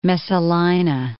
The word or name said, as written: Messalina